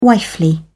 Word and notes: wifely: British English

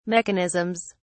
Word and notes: mechanisms: British English

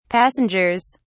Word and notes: passengers: British English

